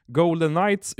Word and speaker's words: Golden Knights: Golden Knights uttalas ju på samma sätt som Golden Knights, det vill säga gyllene nätter, något som Las Vegas som stad är känt för.